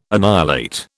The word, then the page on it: annihilate: British English